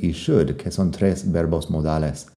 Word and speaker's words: should: porque se escriben parecidas y todos tienen la L que no suena y el mismo sonido de la U corta. Así que would, could, should.